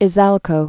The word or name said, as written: Izalco